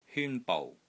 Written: HYUN1 BOU6

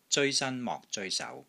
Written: ZEOI1 SAN1 MOK6 ZOEI1 SAU2

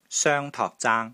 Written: SOENG1 TOK3 ZAANG1

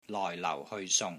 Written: LOI4 LAU4 HEOI3 SUNG3

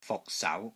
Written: FUK6 SAU2

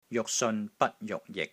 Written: JUK6 SEON6 BAT1 JUK6 JIK6

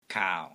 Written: KAAU3

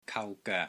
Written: KAU3 GOEK3

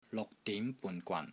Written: LUK6 DIM2 BUN3 GWAN3

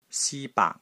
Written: SI1 BAAK3

